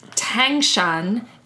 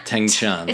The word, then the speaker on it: Tangshan: Tsangshan. – Tangshan. – Okay. – So it's like you're pronouncing a T, but your tongue is in a different position. Anyway, it vaults towards the roof of your mouth, you see.